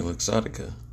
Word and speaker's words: Luxottica: Yeah, Luxottica.